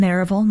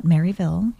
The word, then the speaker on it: Maryville: Maryville, not Maryville. I learned that very quickly mm-hmm.